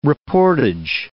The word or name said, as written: reportage